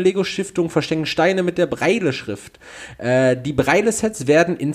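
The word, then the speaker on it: Braille: der dänische Spielwarenhersteller Lego und seine Lego-Stiftung verschenken Steine mit der Blindenschrift, äh, jetzt hilf mir mal kurz, wird es Breil ausgesprochen? Breile-Schrift. Breile-Schrift, ja, okay, also wirklich so, wie es geschrieben wird.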